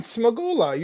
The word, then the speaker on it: Smagula: No, you said it right. Smagula. You said it right. You got it.